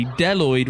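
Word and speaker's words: Bdelloid: Now, it's known as the Deloid rotifer, with a silent B before Deloid, by the way.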